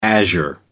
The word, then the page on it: azure: American English